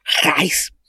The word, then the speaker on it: Reis: Y respecto de Man of Steel, solo para terminar de dar datos, el primer número de la serie es dibujado por Iván Reis, o Rice, no me acuerdo cómo se pronuncia en portugués o en brasileño. – Como si trajeras algo a traer en la garganta. ¡Jais!